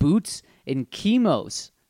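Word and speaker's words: Boots and Kimo's: So if you ever come to Hawaii, you got to check out Boots and Kimos.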